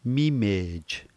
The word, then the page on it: mimej: slow